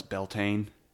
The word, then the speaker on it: Beltane: But you can just pronounce it as Beltane.